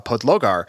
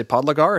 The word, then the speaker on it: Podlogar: He's the nutritionist for Bora Hansgrohe, and he's also a really good Instagram to follow uh, at Tim P- uh, Podlogar. Uh, which is how to pronounce it. Uh, although he says in English, uh, people say Podlogar, and he thinks that's also acceptable. Um, so he's got a really cool Instagram.